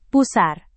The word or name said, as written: pusar